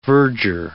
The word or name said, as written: verdure